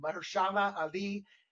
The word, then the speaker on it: Mahershala Ali: How do you pronounce his name? Mahershala Ali.